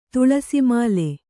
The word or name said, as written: ತುಳಸೀಮಾಲೆ